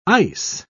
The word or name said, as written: ice